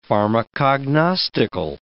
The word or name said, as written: pharmacognostical